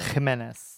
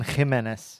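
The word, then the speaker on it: Ximénez: Ximenez. – It's that, I don't know, that Belgian. – Jamon, but then Ximenez.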